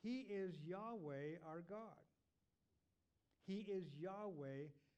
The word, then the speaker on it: Yahweh: So we can always say Yahweh or Jehovah. Sometimes it's pronounced that way. Yahweh, Jehovah, the same name, just pronounced differently. Because in the Hebrew language, there are no vowels. It's just YHWH. And so the vowels are provided. Yahweh, Jehovah, the W can be like a V sound.